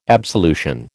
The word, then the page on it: absolution: American English